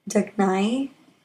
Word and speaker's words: Dugnai: I thought I would introduce the goddess Dagny. It's spelled D-U-G-N-A-I.